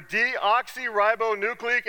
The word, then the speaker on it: deoxyribonucleic: It stands for deoxyribonucleic acid.